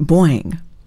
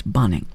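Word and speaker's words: Bonynge: A long ago announcer candidate, not here, but at a station some time ago in my history, pronounced it Boing, which is actually not a bad stab at it, but it is not at all correct. It's Bonning.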